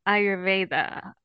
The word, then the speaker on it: Ayurveda: And you did this by studying Ayurveda. Am I pronouncing that right? – Yes, you said it beautifully.